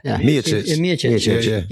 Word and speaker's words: Miocic: And he comes back and he fights Mikovic, right? Not Mikovic. It's I can never say his name, right? How do you say it? – Who's Stipe? – Stipe, yeah. – Miocic.